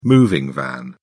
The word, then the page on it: moving van: British English